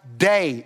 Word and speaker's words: dei: That word must in the Greek is pronounced day. It's spelled D-E-I.